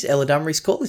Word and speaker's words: Eladamri's Call: Eladamri's call.